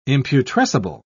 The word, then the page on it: imputrescible: British English